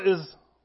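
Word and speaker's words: Ai: Joshua is leading Israel to at first conquer the land that becomes the nation. And they come to this city, spelled A-I, pronounced I, and they're defeated.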